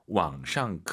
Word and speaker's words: 网上: 우리가 보통 이제 한국어로 인터넷상에서라고 할때 인터넷상 할때 상이 샹이라고 보시면 될것 같습니다. 왕샹 그 선생님 삼성이 지금 무려 세 개예요.